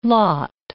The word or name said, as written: lotte